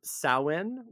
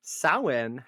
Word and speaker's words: Samhain: Samhain, which is not pronounced Samhain.